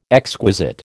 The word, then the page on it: exquisite: American English